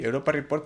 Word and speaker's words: Europa Report: Y se dice así, Europa Report, porque es la luna Europa de Júpiter, amigos.